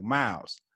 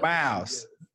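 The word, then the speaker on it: Myles: It's more like M-O-W-E-S, like Miles. – You know what I mean? So you have to say that again. It's like what? – Miles. Miles. Like M-O-W-E-S.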